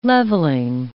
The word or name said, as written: levelling